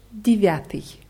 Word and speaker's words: Девятый: Geschrieben wird es mit Je und Ja, also D, Je, W, Ja, T, I, I. Ausgesprochen wird es aber am Anfang mit I und statt Ja, A, Девятый.